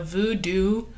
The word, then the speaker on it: Vudu: So for this case, I watched a movie called The Silent Truth, which is on the Voodoo app. And that's spelled V-U-D-U.